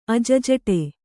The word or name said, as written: ಅಜ್ಝಟೆ